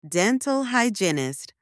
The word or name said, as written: dental hygienist